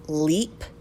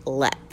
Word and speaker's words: Leap: So it's spelt Leap Castle. – Okay. – L-E-A-P. But I read somewhere that it's pronounced Lep. – Huh. – Like Lep Castle.